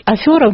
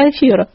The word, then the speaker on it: афера: Такой классический пример борцов за букву Ё – это люди стали говорить слово «свекла» вместо слова «свекла», «афера» вместо «афера».